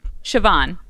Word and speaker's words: Siobhan: Siobhan. – What you got? Give me the proper your pronunciation. – Siobhan. – Siobhan. – Yep. – Okay. – like Siobhan, like S-H-A-V, as in Victor, yeah. A-N.